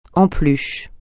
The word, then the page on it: en peluche: français